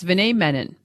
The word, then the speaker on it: Vinay Menon: Toronto Star has a media columnist named Vinay Menon. I have this correct? That's who he is? – Yes, Vinay Menon.